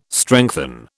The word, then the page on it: strengthen: British English